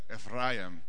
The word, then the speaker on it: Ephraim: Ephraim. You got to roll your R when you say that. Ephraim.